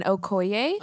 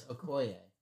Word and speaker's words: Okoye: So Thor and Loki, T'Challa and Okoye. – Oh, it's Okoye.